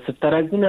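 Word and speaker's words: سفتریاکسون: امی دوا را من فکر میکنم نامش من درست تلفظ کردم، یعنی دکتر سفترکسون نامش از بخلم انتی بیوتیک است یا چطور؟ – بله درست است، سفترکسون است.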